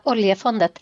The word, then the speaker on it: Oljefundet: I believe it's known as the Oil Fund, the Oljefundet. How is my pronunciation? – Oljefundet. – Oljefundet.